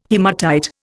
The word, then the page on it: haematite: Indian